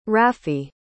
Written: raffi

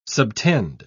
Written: subtend

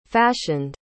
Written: fashioned

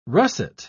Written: russet

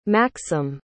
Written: maksim

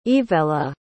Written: yvella

